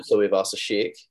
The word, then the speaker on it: Tuivasa-Sheck: Roger Tuivasa-Sheck.